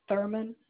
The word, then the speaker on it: Thurman: First name is Eve, E-V-E. Last name is Thurman, T-H-U-R-M-A-N.